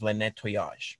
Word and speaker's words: Le Nettoyage: Our name is Le Nettoyage, which just means the cleaning.